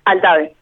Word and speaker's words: Altave: Altave. – Altave, no es tan complejo. Pero bueno, es como se escribe, ¿verdad?